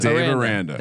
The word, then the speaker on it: Dave Aranda: We might have to do some fading of Dave Arenada. – Well, what's the correctly pronounced? – Dave Aranda.